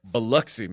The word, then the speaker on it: Biloxi: Biloxi, Mississippi.